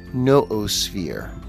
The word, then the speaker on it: noosphere: So I was thinking about his concept of the noosphere. Now that's spelled kind of like it sounds, N-O-O-S-P-H-E-R-E.